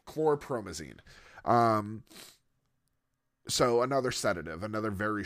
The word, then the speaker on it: chlorpromazine: He invited him inside, and noticing Wallace's acute state of tenseness and depression, volunteered to inject him with chlorpromazine, with the explanation that this would calm down and relax him.